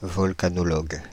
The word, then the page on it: volcanologue: /vɔl.ka.nɔ.lɔɡ/